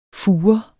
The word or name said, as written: fure